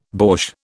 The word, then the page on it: borsch: British English